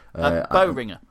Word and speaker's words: Boehringer: I'm going to try and pronounce his name, Moritz Boehringer. – Boehringer, I believe. – Yeah, that'll do.